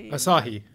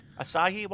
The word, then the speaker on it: Asahi: TV, is it Asahi. – Asahi.